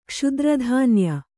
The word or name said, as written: ಕ್ಷುದ್ರಧಾನ್ಯ